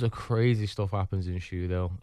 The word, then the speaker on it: Shudehill: Do I say it right when it's called Shudehill? – Shudehill.